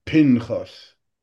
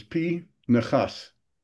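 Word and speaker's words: P. Nechas (פנחס): Everybody says Pinchas, which I say also when I give my name, but the correct pronunciation is P. Nechas. The shva under the nun is a shva na, not a shva nach.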